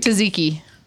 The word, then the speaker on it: tzatziki: Zatsky sauce? – It's Greek. Greek. – Tzatziki.